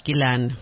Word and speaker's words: Ghislaine: They pronounce it Ghislaine.